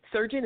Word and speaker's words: Surgeon: Surgeon is spelled just like a doctor, S-U-R-G-E-O-N.